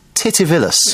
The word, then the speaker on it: Titivillus: In the Middle Ages, mistakes and spelling errors made while writing were blamed on a typo demon named Titivillus.